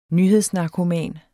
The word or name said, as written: nyhedsnarkoman